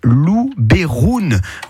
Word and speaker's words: Luberon: En provençal, en fait, le E se prononce E. Donc, si vous dites Luberon, c'est presque la bonne prononciation d'origine. Voilà. Parce que la prononciation de base en provençal, c'est Luberoun.